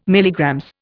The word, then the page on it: MG: Indian